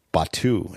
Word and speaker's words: Batuu: Batuu, is that how you pronounce it? – Yeah. – B-A-T-U-U, which I think was the right decision.